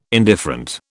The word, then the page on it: indifferent: British English